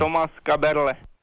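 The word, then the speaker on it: Kaberle: Thomas Caberle. – There you go. It is. It's Caberle.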